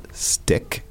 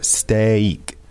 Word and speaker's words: steak: So it's not stick. It's not a short sound. There are actually two vowel sounds here. Steak, right? – Yeah, like a long sound there. – Mm-hmm, yeah, steak. And what about the spelling? – It's spelled S-T-E-A-K.